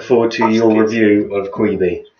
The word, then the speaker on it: Quibi: And it's pronounced Quibi.